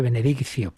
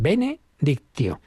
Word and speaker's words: benedictio: Y es obvio, enseguida lo veremos, en latín benedictio, Solemos hacer la pronunciación italiana que suena así más suave, benediccio, pero es benedictio.